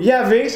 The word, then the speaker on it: Yahvé: Et son nom, là, tenez-vous bien, là, c'est YHWH. Essayez de me prononcer ça, là. C'est pour ça qu'on dit Yahvé. Il n'y a pas de voyelles, c'est juste des consonnes.